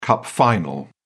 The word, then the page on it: cup final: British English